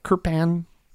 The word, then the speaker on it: kirpan: is that how you say it kirpan kirpan i think you nailed it yeah.